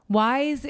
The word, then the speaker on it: YSE: Wise, it's spelled Y-S-E. It's pronounced to be, you know, wise.